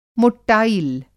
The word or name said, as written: मोट्टायिल